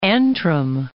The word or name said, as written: Antrim